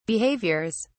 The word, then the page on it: behaviours: British English